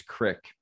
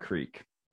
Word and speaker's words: crick: Yeah, I do appreciate too that you pronounce it as crick and not creek.